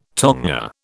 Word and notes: tonga: British English

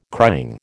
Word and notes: crying: American English

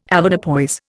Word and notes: Avoirdupois: Indian

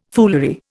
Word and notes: foolery: Indian

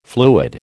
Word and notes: fluid: American English

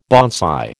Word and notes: bonsai: American English

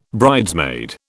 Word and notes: bridesmaid: British English

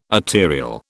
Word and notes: arterial: British English

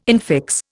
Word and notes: infix: Indian

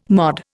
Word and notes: MOD: Indian

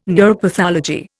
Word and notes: neuropathology: Indian

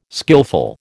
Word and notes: skilful: American English

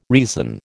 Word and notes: reason: American English

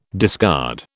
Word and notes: discard: British English

